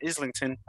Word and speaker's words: Islington: Yes, it's pronounced Islington.